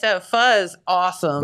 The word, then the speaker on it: pho: Speaking of new things, down here in Jackson, the new pho restaurant has opened.